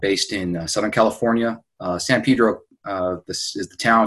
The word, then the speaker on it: Pedro: Pedro, pronounced Pedro as opposed to San Pedro, the way that you could tell the townies from the out-of-towners.